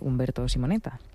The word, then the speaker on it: Umberto Simonetta: pero las escribe Umberto Simonetta. O como bien se pronuncie. – Lo has pronunciado perfectamente bien.